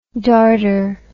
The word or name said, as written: dartre